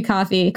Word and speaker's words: Ko-Fi: It's spelled Ko-Fi, but I guess it's supposed to be coffee.